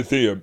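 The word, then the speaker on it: Athenaeum: And is it the Anatheum? Am I saying that right? – Yeah, Anatheum, yeah.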